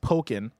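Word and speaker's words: Pokkén: Estimus says Pokin is pronounced Pokin, like Pokemon.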